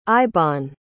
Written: ibon

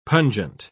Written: pungent

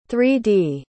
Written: 3-D

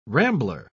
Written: rambler

